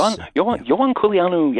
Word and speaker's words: Ioan Culianu: So tell me about, I believe his name is pronounced Ioan Culianu, the Romanian, and his... – Ioan Culianu, yes.